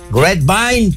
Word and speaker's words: Grapevine: Grapevine, Texas.